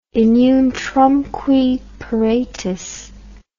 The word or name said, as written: in utrumque paratus